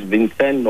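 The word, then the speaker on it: Vincent: Δεν είναι τυχαίο ότι ο Τεό και ο Βενσάν, ο Βινσέν όπως τον προφέρει κανείς, βρίσκονται στον χώρο, τον ταφικό χώρο δίπλα-δίπλα.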